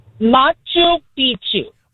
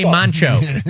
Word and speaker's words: Machu Picchu: Machu Picchu. – Why do I always want to say Mancho? – I don't know.